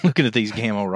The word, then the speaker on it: Gamo: G A M O.